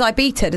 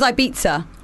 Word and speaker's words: Ibiza: How do you say Ibiza? – I think she says Ibiza, doesn't she? – Play it again. – Ibiza. She says Ibiza.